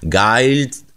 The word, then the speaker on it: Guild: Pensate che la produzione inventò per l'occasione uno slogan in rima che diceva così: Guild rhymes with wild, cioè Guild fa rima con selvaggia.